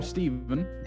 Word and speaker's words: Stephen: That name, I always, some parents spell it Stephen, S-T-E-V-E-N, and then spell the way. – So I'm Stephen, S-T-E, so the American spelling is the V-E-N. – Right. – But it's pronounced exactly the same, Stephen.